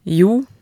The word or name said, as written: jo